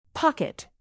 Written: pocket

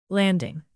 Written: landing

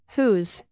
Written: whose

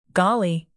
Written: golly

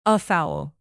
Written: afoul

